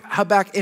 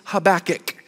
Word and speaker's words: Habakkuk: So I'm going to say it one more time. It's Habakkuk. That's what it is.